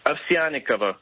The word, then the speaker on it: Ovsyannikova: Ovsyannikova.